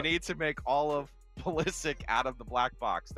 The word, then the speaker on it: Pulisic: Pulisic. I'll get his name right eventually. – Pusilic.